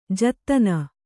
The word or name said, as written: ಜತ್ತನ